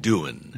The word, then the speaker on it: Doohan: He pronounces it doing. – Doing. – Yeah. – I could. – It's like he says, what are you doing?